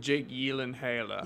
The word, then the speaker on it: Gyllenhaal: With Jake Gyllenhaal.